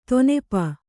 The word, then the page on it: ದಿನಪ: dinapa